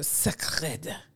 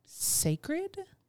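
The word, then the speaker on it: Cécred: It's spelled C-E-C-R-E-D. There's a little accent over the E. I think it's sacred. Sacred? – Sacred.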